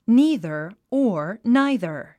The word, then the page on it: neither: níːðə r; ニーザァ